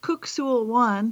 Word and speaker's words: Kuk Sool Won: It's actually pronounced Kuk Sul Wan for anybody who's out there who practices this. That it looks like cuck, but it's kook.